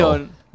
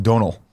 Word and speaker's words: Domhnall: Close Donal. To Donal. Donal. Like Tonal. His first name is pronounced Donal.